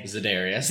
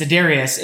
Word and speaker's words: Za'Darius: Sorry, and and, and Darius Zaire Day or I can't say his name. Za'Darius. Za'Darius is not fuck is playing, and right now Preston Smith is out, or, or Kevin King was out.